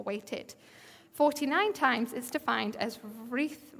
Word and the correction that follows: writhing: writhing in pain. Is that how you say it? Writhing, thank you.